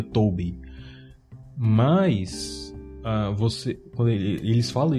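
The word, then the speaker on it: T0-B1: Eles pronunciam o nome dele dessa outra forma, né? Que é T0B1. Só que o zero em inglês também você pode pronunciar como O. No caso, a letra O, né? Como aqui a gente pronuncia 6-6 e por aí vai. E aí eles dizem T-O-B-1.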